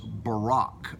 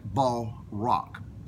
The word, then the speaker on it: barak: You know, the word blessed there in the Hebrew is barak, barak, pronounced B-A-W-R-A-K.